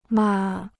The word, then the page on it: 嘛: ma